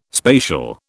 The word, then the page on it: spatial: British English